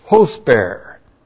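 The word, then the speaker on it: Hosper (ὥσπερ): And then we have the comparative adverb hosper. H long O S P E R. Hosper.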